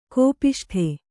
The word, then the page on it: ಕೋಪಿಷ್ಠ: kōpiṣṭa